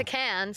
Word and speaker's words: Cannes: But the one prior to this went to Cannes. Wow. I mean, to Cannes, sorry, is how you properly say.